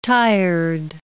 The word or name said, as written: tired